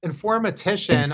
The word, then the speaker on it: informatician: Uh, like, what what sort of background makes for a good infomortician? Infomortician. – Yeah. How do you, how do you say that again? – yeah, informatician.